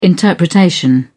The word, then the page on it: interpretation: British English